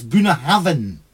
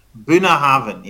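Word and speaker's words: Bunnahabhain: I think it's with a B and an H together. Is it a V sound? So it's Bunahavan. – Bunahavan, yeah, Bunahavan. Absolutely right.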